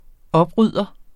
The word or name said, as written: oprydder